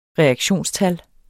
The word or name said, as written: reaktionstal